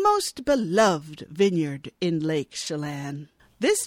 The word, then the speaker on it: Chelan: Oh, that's the other one that I did phonetically. Lake Chelan, Washington, because I never would have pronounced that correctly. – No, it's C-H-E-L-A-N. I would have been like Chelan.